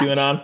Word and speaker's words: QAnon: How do you say that? Q, Quantra? I don't know, whatever that. – QAnon. – I know QAnon.